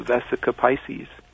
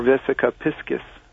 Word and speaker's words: Vesica Piscis: And you end up creating two intersecting spheres, and in two dimensions, that would be called the Vesica Pisces, or Vesica Piscis, as it's sometimes pronounced.